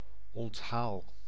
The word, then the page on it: onthaal: [ɔn.taːl]